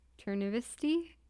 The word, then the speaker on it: Chernivtsi: Chernivtsi, a Ukrainian city which at the time was a part of the Soviet Union.